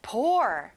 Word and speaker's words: poor: 가난한, 불쌍한, poor. 친구들, O가 두 개지만 U 소리가 안 나고 OR 소리가 나요.